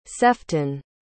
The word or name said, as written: sefton